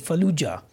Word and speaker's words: Fallujah: Fallujah.